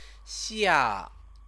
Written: xia

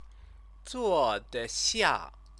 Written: zuodexia

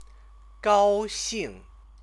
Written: gaoxing

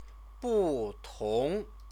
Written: butong

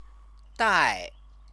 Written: dai

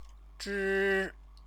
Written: zhi

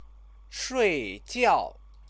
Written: shuijiao

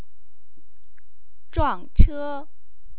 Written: zhuangche

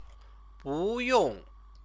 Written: buyong

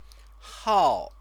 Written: hao

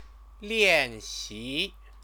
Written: lianxi

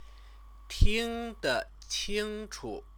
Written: tingdeqingchu

